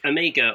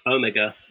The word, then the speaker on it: Omega: Omega or Omega, depending on how you want to pronounce it,